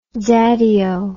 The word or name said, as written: daddyo